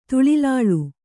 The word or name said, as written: ತುಳಿಲಾಳ್